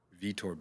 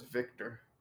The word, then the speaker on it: Vitor: Vitor. You said Vitor? – Vitor Belfort. – Oh, I thought it was Victor. – No, there's no C.